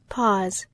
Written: pause

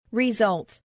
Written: result

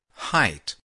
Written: height

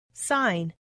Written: sign

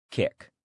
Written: kick

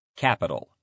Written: capital

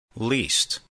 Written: least

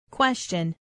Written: question

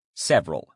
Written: several

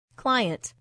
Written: client

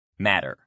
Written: matter

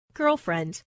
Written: girlfriend

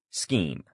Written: scheme

